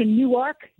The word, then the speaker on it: Newark: Much closer, really, to Newark, Delaware, where you must put that accent on the second syllable.